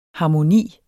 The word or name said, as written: harmoni